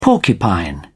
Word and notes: porcupine: British English

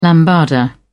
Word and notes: lambada: British English

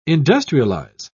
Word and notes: industrialise: British English

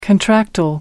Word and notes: contractile: American English

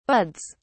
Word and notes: buds: British English

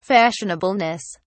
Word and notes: fashionableness: British English

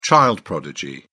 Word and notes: child prodigy: British English

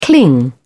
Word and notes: cling: British English

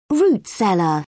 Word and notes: root cellar: British English

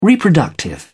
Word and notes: reproductive: British English

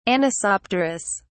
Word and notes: anisopterous: British English

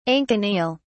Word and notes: anconeal: British English

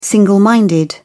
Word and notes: single-minded: British English

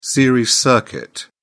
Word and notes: series circuit: British English

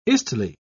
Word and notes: istle: British English

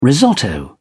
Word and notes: risotto: British English

